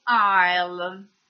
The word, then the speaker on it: aisle: E le varie file o corridoi si chiamano aisle. Scritto A-I-S-L-E, quindi questa S che trovate scritta non la dovete pronunciare.